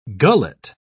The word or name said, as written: gullet